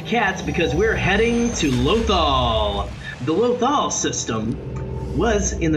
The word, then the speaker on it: Lothal: He's going to tell us all about, is it pronounced Lothal? – It's pronounced both in the show. I think Lothal is what you hear the most though.